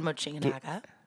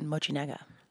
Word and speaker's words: Mochinaga: Throughout the 1960s, the Animagic productions were headed by Japanese stop motion animator Tad Mochinaga. – Wait, what? You said it right. – Yeah. – Say it one more time for me. – Tad Mochinaga.